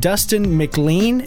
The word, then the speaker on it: Dustin McLean: Today, we have Dustin McLean.